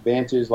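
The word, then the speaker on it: Vantiij: So what we're up to now is I run Vantage, so it's pronounced Vantage like you normally would, V-A-N-T-A-G-E, but we wanted to do it unique, so it's V-A-N-T-I-I-J.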